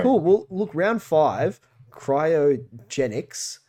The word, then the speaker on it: cryogenics: I know so little about this mission that I hadn't even said the name correctly until just now. – well done. – I was calling it cryogenetics, which is not correct.